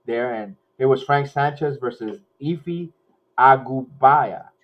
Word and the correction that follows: Ajagba: Efe Ajagba. – Ajagba.